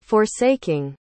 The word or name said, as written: forsaking